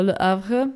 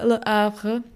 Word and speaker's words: Le Havre: v městě Le Havre, což je vtipný, protože to město vlastně nejde moc dobře vyslovit, protože francouzi nemají háčko a oni ho nevyslovují. Oni ho jakoby mají v abecedě, což je ještě divnější, ale neumějí ho vyslovit.